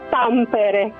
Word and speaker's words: Tampere: Ciao Valeria, allora prima di tutto Tampere, quindi l'accento va proprio all'inizio.